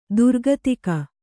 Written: ದುರ್ಗತಿಕ

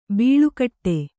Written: ಬಾಳುಗೇಡಿ

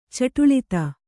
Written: ಚಟುಳಿತ

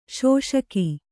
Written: ಶೋಷಕ

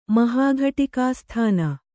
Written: ಮಹಾಘಟಿಕಾಸ್ಥಾನ

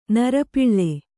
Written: ನೃಪಾಲ

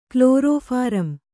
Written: ಕ್ಲೋರೋಫಾರಂ

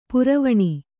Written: ಪರ್ವಣಿ

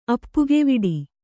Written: ಅಪ್ಪುಗೆವಿಡಿ

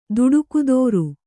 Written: ದುಡುಕುದೋರು

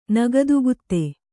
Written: ನಗದುಗುತ್ತೆ

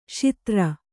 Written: ಶೂದ್ರ

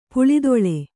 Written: ಪುಲಿದೋಲ್

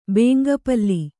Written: ಬೇಂಗಪಲ್ಲಿ

